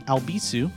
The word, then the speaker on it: Albisu: And if you'd like to follow me on social media, that's at Alex Albisu. My last name is spelled A-L-B as in boy, I-S as in Sam, U.